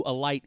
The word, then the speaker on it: Alight: Am I saying that right? A-L-I-G-H-T? Alight? Is that? – Yeah, just like Alight. I mean, just the word Alight.